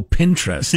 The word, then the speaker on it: Pinterest: The way you said it, it sounded like pederast. Donnie. – How do you say it? – Pinterest. – I don't use it. – It's like interest.